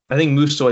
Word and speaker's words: Musou: So is currently projected to be I think Musou is the proper way to pronounce it.